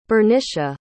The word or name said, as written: bernicia